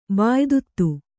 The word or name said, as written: ಬಾಯಿದುತ್ತು